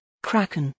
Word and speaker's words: Kraken: Kraken. In British English, Kraken.